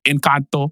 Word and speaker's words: Encanto: Encanto. No, Encanto is how it's pronounced.